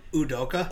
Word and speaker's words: Udoka: Like if Oduka, is it Oduke? Did they say it right? – Ime Udoka. – Oduka, sorry. – Udoka. – Udoka. Yeah. We'll work on that.